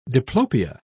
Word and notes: diplopia: British English

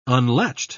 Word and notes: unlatched: British English

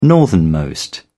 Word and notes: northernmost: British English

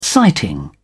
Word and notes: sighting: British English

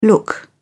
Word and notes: look out: British English

